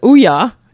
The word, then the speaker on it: Ouya: It's spelled O-U-Y-A. And apparently it's pronounced Ouya, as in Booyah, but without the B.